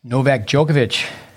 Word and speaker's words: Novak Djokovic: Novak Djokovic, Joe Wilfried Tsonga, correct my pronunciation.